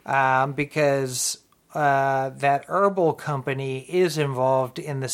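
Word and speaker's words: Herbl: and Robin Big fame back in the day on MTV, have partnered up with the company Herbal, H-E-R-B-L. I'm pretty Herbal. – Sure they pronounce, yeah. Herbal.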